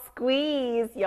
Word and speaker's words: squeeze: Squeeze. 여기 중간에 quit 소리가 들렸는데요. 그럼 우리 발음 다시 들려줄까요? – Quick, quick, squeeze.